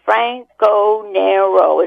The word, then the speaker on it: Franconero: He said, how do you pronounce your name again? So I said, Franconero, as if teaching him a foreign language.